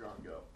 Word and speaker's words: drongo: It's a drongo. – A drongo? It says drogo in the book. Drongo. All right, there is, apparently there's a bird called a drongo bird that's spelled D-R-O-G-O. It said drongo. Okay, anyway, we'll go with drongo.